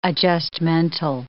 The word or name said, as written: adjustmental